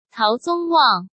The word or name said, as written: Tao Zongwang